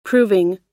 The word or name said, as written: proving